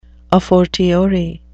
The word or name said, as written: a fortiori